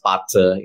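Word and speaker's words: Patsa (八字): And then there's a link there called BAZI, pronounced Patsa in Chinese.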